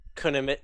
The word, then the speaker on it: Kunimitsu: Well, they've officially announced the new characters or the DLC characters, which are Kunimitsu. I said that right, correct? – Kunimitsu, yeah.